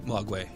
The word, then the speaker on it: Mogwai: What three rules must be observed if you own a Mogwai as a pet? Right? Is that the pronunciation of Mogwai? – Mogwai.